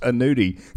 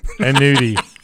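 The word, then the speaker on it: Aneudy: His name's pronounced Anudi. – Anudi.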